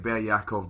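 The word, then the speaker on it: Be'er Ya'akov: Ber Yaakov, am I pronouncing that? – Yeah, right? – Ber Yaakov, but it's spelt Beer Yaakov, right.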